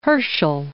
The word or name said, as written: Herschel